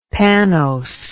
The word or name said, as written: pannose